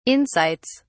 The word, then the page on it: insights: British English